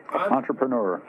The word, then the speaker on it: entrepreneur: I always mispronounce the word, entrepreneur? – Entrepreneur.